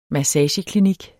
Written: massageklinik

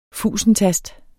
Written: fusentast

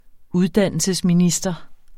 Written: uddannelsesminister

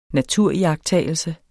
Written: naturiagttagelse

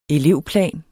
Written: elevplan

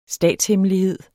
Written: statshemmelighed

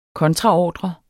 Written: KONTRAORDREN

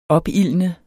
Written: opildne

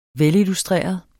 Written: velillustreret

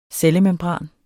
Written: cellemembran

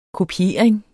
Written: kopiering

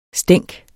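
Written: stænk

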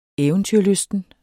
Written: eventyrlysten

